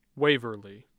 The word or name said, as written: Waverly